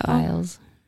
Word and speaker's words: Biles: So Simone Biles, am I pronouncing that right? – I think so. – Biles. – Yeah.